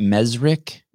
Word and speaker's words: Mezrich: Mezrich? – Yes, that is correct.